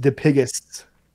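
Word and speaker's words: Dipygus: The name, it's D-I-P-Y-G-U-S. But it is pronounced Dipigus, which is actually like a deformity that you get when you were born with like extra legs and shit.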